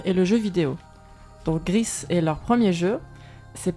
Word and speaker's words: Gris: Et c'est un jeu espagnol je crois qui s'appelle Gris. – Gris, qui se prononce ainsi parce que c'est un jeu espagnol, est sorti le 13 décembre, et donc c'est un jeu du studio Nomada, publié par Devolver.